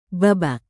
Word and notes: babak: indonesian

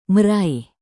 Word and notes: meraih: Indonesian

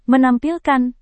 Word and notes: menampilkan: Indonesian

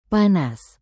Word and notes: panas: Indonesian

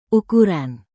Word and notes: ukuran: indonesian

